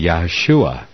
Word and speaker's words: Yahshua: And the name of Joshua in Hebrew, phonetically, at least correctly pronounced, would be Yahshua, which would be the same name as the Messiah.